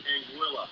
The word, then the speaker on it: Anguilla: Pronunciation of one of the Caribbean islands. The most common pronunciation I hear is Anguilla. However, the correct pronunciation is Anguilla. I've been there. The natives say Anguilla. It's Anguilla. Say Anguilla.